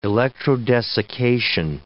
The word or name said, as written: electrodesiccation